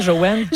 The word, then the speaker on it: Jowanne: Ça aussi, ça vous rend heureuse hein, de dire Joanne. – Joanne, il faut dire que ça s'écrit J-O-W-A-N-N-E.